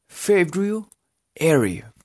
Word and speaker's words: February: Porque sería February. February. Entonces, February. Primero digan la primera parte, February. Y luego pongan el airy, la terminación airy.